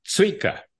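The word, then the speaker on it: țuică: It's a ts sound, tzuika.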